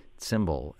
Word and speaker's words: tsimbl: And to complete people's education here, that is spelled T-S-I-M-B-L. Cymbal. And then there's, what's this other instrument, K-A-V-A-L, Kaval?